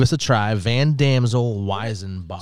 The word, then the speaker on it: Weizenbock: A Weizenbock. I believe that's how you say it, a Weizenbock.